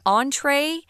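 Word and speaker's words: entrée: The reason why that word entree has that funny line over it is because it's French. So it's pronounced entree.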